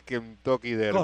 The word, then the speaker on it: Kentucky Derby: válidos para la clasificación al Kentucky Derby.